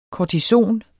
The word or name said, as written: kortison